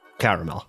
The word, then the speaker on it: caramel: Caramel. – Oh, you do say three syllables.